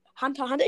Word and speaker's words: Hunter x Hunter: Ob der aus Hunter x Hunter, Hunter x Hunter, ich hab's richtig gesagt, aus Hunter x Hunter oder was ever, whatever ist.